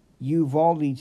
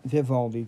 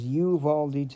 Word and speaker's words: Uvalde: And the town in which this sad massacre happened would have been Uvalde, Texas. Not Vivaldi, Texas. I do apologize for mispronouncing the name. Once again, the town in which I was trying to pronounce was Uvalde, Texas.